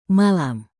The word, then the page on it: malam: Indonesian